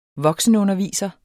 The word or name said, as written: voksenunderviser